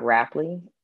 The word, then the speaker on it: Rapley: So my corner on Instagram is Tanya with OTONYA dot Rapley.